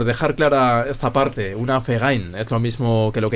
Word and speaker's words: Verein: Pues aquí en este punto es donde sale el primer término en alemán, Verein. Lo he dicho bien, York. – Verein. – Verein. – Exactamente.